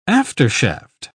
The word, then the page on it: aftershaft: British English